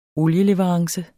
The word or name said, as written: olieleverance